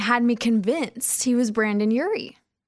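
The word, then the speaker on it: Brendon Urie: You brought up Brandon Urie. – Yes. Yeah. Lead singer. – Am I saying that right? – Yeah. Brandon Urie, Panic at the Disco.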